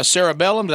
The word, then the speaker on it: cerebellum: Prior studies implicated the cerebellum.